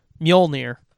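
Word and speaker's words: Mjölnir: We had to figure out our pronunciation of it. So for the movies, at least, it is Mjolnir.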